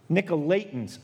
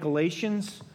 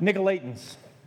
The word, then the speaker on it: Nicolaitans: Now, I've been saying this wrong. Mark corrected me the other day. I think he enjoyed it. Yeah. How do you pronounce it again? Nicolaitans. I've been calling them Nicolaitans. I don't think it matters because they're not around anymore. But Nicolaitans.